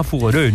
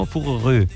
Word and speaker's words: Fougeray: Esto se pronunciará Fougeray, ¿no? Fougeray. – Sí.